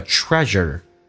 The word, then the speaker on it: treasure: 下面比较难的一个单词叫 treasure，日这个发音它有点像日本人的日叫日，然后轻度卷舌日，